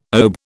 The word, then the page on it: OBE: British English